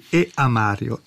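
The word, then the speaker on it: E. A. Mario: Immagino Christian Gilardi si riferisca a Santa Lucia Lontana, di quello straordinario e fecondissimo compositore che fu E a Mario. Ecco, non è che non voglio dire il nome, e si pronuncia proprio così, E a Mario.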